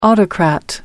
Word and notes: autocrat: American English

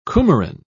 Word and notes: coumarin: British English

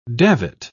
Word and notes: davit: British English

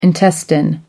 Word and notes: intestine: American English

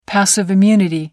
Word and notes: passive immunity: British English

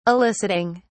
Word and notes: eliciting: British English

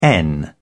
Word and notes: n: British English

